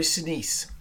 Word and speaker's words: Sinise: Gary Sinise.